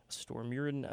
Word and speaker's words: Stormurinn: The new track they just released, which translates into The Storm, Stormurin, I think it's how it's pronounced.